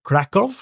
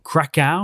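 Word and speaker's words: Kraków: We've been to Krakow or Krakow, however you like to pronounce it.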